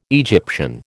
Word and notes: Egyptian: American English

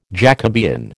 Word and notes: Jacobean: American English

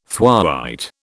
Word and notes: thwaite: British English

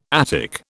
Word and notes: Attic: British English

